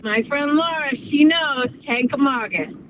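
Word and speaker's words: Kancamagus: Apparently, I'm saying Kankamangus wrong. – I've been saying it wrong my whole life, I guess. – How do we say Kankamangus? – Kankamangus. There's no N in Kankamangus. It's Kankamagus.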